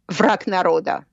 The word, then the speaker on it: Враг народа: Враг народа.